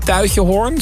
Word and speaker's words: Tuitjehoorn: Deershoorn, dat is uit mijn hoofd, is bij Tuitjehoorn, zeg ik dat goed? – Ja, dat klopt.